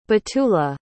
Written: batula

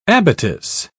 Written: abatis

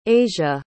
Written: aja

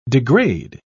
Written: degrade

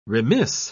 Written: remiss

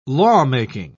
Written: lawmaking

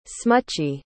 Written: smutchy

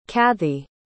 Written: cathey

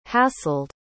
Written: hassled

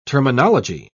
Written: terminology

